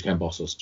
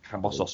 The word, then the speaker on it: Kambosos: George Kambosos Jr. Kambosos Jr., that's right.